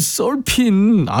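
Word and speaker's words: Surfin': 진짜 매주 일요일마다 지금 청계산 날다람쥐인데 4768님이 형님 자꾸 슬픈 USA라고 하는데 힘나는 노래가 많나요? 슬픈 아니고 썰핀. 아 진짜 왜 이래요 오늘. 슬픈 USA 그것도 말되네. 아이 참 슬픈 아닙니다. 썰핀 USA예요.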